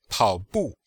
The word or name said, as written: pao3bu4